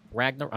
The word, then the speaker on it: Ragnarok: I like the Thor Ragnarok. I'll pronounce it wrong. Ragnarok. Is that what it's called? – No, yeah, that's right.